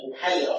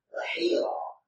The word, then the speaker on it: Bahira: Sebagian orang membacanya Buhayra, yang sahih adalah Bahira Ar-Rahib.